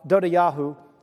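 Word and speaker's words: Dodavahu: Then Eleazar, son of Dod, Dodawa, Dodayahu, that's actually how you pronounce it,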